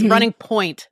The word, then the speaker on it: Running Point: Yeah, it's running point, not like boiling point.